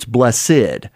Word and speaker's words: blessed: Now, as I mentioned last week, when we say the word blessed, in this case, it actually has two syllables, because an adverb, noun, or adjective all have the two syllables here. So it's blessed.